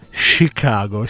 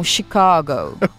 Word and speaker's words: Chicago: Anzi, anche lì si dice, ho scoperto che si dice Chicago. – Sci e anche la A, non è proprio una A, ma proprio loro la pronunciano un po' con una mezza O, un Chicago.